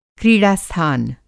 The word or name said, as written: क्रीड़ा-स्थान